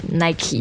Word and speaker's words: Nike: 现在我觉得越来越多的人会说 Nike, 但是耐克刚进中国的时候我们按照标准英文发音的规则都会读耐克。应该是 Nike。